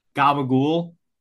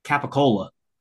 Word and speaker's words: capicola: The funny thing is, like, people that, like, think the word gabagool is spelled gabagool, like, it's not. It's just how it's said here. – How do you spell it, then? – Capicola.